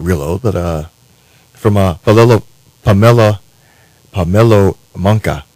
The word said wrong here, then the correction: Pamelo Munka: out of Democratic Republic of Congo. And I think Dave's going to give me the correct pronunciation on that. – Pamelo Munka. – Pamelo Munka. Thank you.